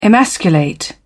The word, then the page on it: emasculate: British English